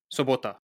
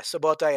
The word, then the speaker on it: Sobota: How do you say Saturday in Slovenian? – Sobota. – Ah, Sobota. In Polish, it's Sobota.